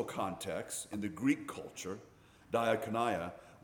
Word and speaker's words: diakonia: The word for ministry that's used here in 2 Corinthians is diakonia. There you go. I looked it up and listened to several different pronunciations. Diakonia.